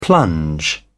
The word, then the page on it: plunge: British English